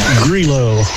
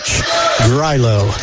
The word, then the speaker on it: Greulach: Back out to number 4, Emily Grilo, I believe, is how he told me to pronounce that. G-R-E-U-L-A-C-H, Grilo.